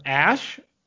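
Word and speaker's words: Æ: AE is somehow pronounced Ash,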